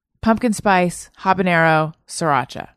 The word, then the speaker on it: Sriracha: Sooner Magic says, see flavor of the month, pumpkin spice, habanero, sriracha. Am I saying that right? – Sriracha. Sriracha. – Sriracha. – Sriracha. – That's right. I've been corrected before.